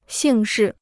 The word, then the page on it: 姓氏: xìng shì